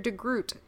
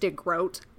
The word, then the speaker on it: DeGroot: But then the secretary gets very concerned about this and was like, it's pronounced DeGroat, like boat.